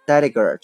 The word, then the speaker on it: delegate: OK，第二个单词呢叫做 delegate，delegate，d e l e g a t e，delegate 这个词啊也有很多读音，比如说 delegate 也是可以的。